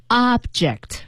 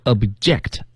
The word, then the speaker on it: object: 嗯 ,object, 这是做动词的时候，当然大家一定也知道了，它还有一个读音啊，叫做 object。 – 对，那您仔细听哈，做动词的时候是 object, 而做名词的时候是 object, 也就是说前面的重音往前提，而且后面那个 J-E-C-T 那个 E 要读成一个小 E 里。